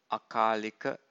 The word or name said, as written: akalika